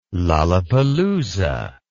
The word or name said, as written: lalapalooza